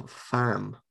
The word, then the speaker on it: Pham: Complete disappointment, except for Pham. Pham? – Pham? – Pham. – But there's an H in it. – There's an H, yeah. – It's spelled P-H-A-M.